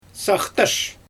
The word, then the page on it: saxtysh: Xyzyl